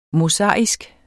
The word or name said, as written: mosaisk